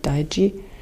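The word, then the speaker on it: Taiji: Also Tai Chi wäre jetzt die deutsche Übersetzung, aber ausgesprochen wird es eher wie Daiji.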